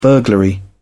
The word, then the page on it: burglary: British English